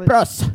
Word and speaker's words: Tsipras: I mean, I guess the T is silent. – Tsipras. – T-S-I-P-R-A-S.